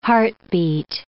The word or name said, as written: heartbeat